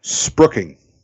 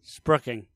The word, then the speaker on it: spruiking: S-P-R-U-I-K-I-N-G. – Say it again. – S-P-R-U-I-K-I-N-G. – Sprigging. – Sprooking. – Sprooking. Okay. Because there's an I for no particular reason right in the middle there.